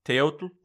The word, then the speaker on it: Teotl: but abstractly central to all of this is Teotl. I hope I'm pronouncing that correctly. – Teotl. The L at the end is silent.